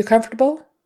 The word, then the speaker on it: comfortable: Are you comfortable? so comfortable. That fort syllable, fort, kind of gets lost a little bit.